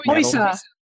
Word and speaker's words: Huse: Cami Huse. I hope I said that right. – Huysa. – Cami Huysa.